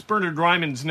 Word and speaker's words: Bernard Raimann: At left tackle, you've got Bernard Raimann against Matt Pryor, and that's how you pronounce Bernard Raimann's name. We were with a guy, a sports writer or some kind of a writer from Austria yesterday at the Colts complex, and he kept saying Bernard Raimann. That's how you pronounce it.